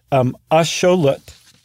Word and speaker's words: axolotl: So the word axolotl, what we call axolotl, is actually axolotl, even though it's spelled the exact same way. In the Nahuatl language, A-T-L is pronounced lut, and X is very often pronounced sho. It can also be pronounced wa.